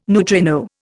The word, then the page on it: neutrino: Indian